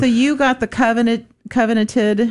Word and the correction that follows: coveted: how do you say that word? – Coveted.